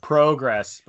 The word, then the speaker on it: progress: Yeah. Progress, but it's progress. It's progress. It's a short O sound. Okay, that's why we invented short and long vowel sounds over here so that we could excuse our incredibly like abhorrent pronunciation of things.